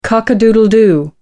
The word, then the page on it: cock-a-doodle-doo: American English